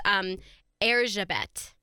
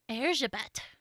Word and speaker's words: Erzsébet: Elizabeth is obviously the English version of her name, but her name is pronounced Erzsébet. – Erzsébet. – Mm-hmm. So that's her Hungarian pronunciation.